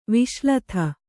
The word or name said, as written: ವಿಷಲತೆ